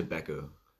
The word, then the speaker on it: BECU: You should to BECU. Yeah. To Beko. – Yeah, as it's pronounced.